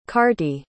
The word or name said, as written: carty